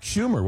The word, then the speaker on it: Schumer: I believe that was Chuck Schumer, was it not? – Yes. The senator's name rhymes with rumor.